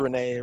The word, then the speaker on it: Renette: Yeah, it's spelled Renette. It's pronounced Renee.